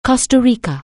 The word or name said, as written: Costa Rica